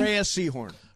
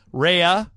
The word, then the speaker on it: Rhea Seehorn: Do you pronounce her name Ray? – Rhea Seehorn. – Rhea, yeah. – Two syllables? – Yep.